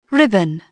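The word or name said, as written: ribbon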